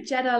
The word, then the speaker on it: Geta: You always say Geta like the car.